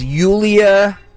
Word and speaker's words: Yulia: All right, so her name is Yulia, like Julia, but yes, pronounced Yulia.